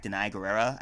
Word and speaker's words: Danai Gurira: I didn't go attack Danai Gurira. I could be pronouncing her name wrong. – Well, you just attacked Twitter. Her right there, didn't you? – Yeah, I did, actually. – I think that is how you say her name, actually.